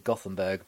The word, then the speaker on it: Göteborg: Evening, as we mentioned in the intro, the evening that we're recording, Rosengard have just come from 2-1 down away at, I think Anita Asante pronounced it as Göteborg. Now, I would pronounce it as Gothenburg, but I think she pronounced it as Göteborg or something like that anyway when we had her on the show a little while back.